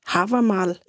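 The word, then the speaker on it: Hávamál: Men jeg synes, det er meget sjovt, det her med, du har skrevet sådan en lille digt, som er Havamal, eller hvordan udtaler du det? Jamen, det er rigtigt nok.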